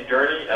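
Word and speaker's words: Durny: My name is Andy Durney.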